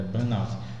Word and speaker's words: burnout: Se pronuncia burnout ou burnout? – Essa é a grande questão, né? – É burnout. – Burnout. – É, porque é de queima, né? – Só para poder os nossos telespectadores saber.